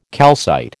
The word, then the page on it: calcite: American English